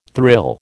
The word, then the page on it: thrill: American English